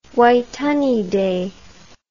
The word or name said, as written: Waitangi Day